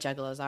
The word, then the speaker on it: Juggalo: And also, I thought it was pronounced Juggaloo. Fuck. So embarrassed. – Well, now you know. – Now I know. Oh, I know. DJ laughs so hard at me for mispronouncing it.